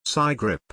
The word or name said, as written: cigrip